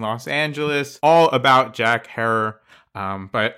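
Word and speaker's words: Herer: So, of course, we're talking about the legendary author and activist Jack Herrera, rhymes with terror.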